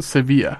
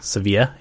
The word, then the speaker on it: Sevilla: I think it's pronounced Sevilla. – Sevilla, yeah. – At least that's how the soccer team pronounces it.